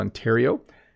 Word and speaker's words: Ontario: Which is the best pronunciation, Ontario? So I live in the province of Ontario, and that's how I say it.